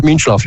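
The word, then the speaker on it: Mintzlaff: dus de, nieuwe Mateschitz, dat, die heet geloof ik Mins, Minslav, zeg ik dat goed? Ja, Minslav, ja.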